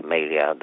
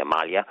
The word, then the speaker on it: Malia: A fost vorba spre a relua conceptul lui Martin, ai pronunț Melia, dar cred că e Malia, legat de o analiză a lui Abdurrahman Aftorhanov, de o partocrație ideocratică.